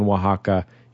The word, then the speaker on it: Oaxaca: Maybe that's – I'm sure he doesn't mispronounce – It's definitely, I've never heard it pronounced anything other than Oaxaca, including when I was there with my Spanish-speaking mother.